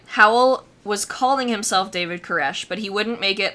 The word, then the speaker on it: Koresh: The first being King David, and the second, Cyrus, which in the traditional Hebrew would be spelled and pronounced Koresh.